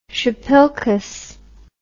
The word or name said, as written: shpilkes